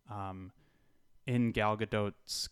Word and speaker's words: Gadot: Gadot, thanks to Gal Gadot. – Gal Gadot, Gadot, Gadot, Gal. – Gal Gadot, Gal Gadot. – Just, I want to remind everyone that's how you say it.